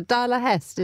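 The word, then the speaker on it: dalahäst: We've had a pronunciation correction from our producer, Marcus Hibby. It's a Dala Hest, is that right, Marcus? Right, thumbs up from there.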